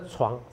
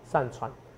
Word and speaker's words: 上船: 因为你九月之前你要上船，上那床上。船上船，有没有？我发音不太好，所以你帮我发一下。